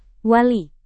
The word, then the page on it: wali: Indonesian